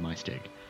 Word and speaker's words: Maesteg: And, and if you kept following this for like the rest of the day, you'd end up in in in, in, in Maesteg.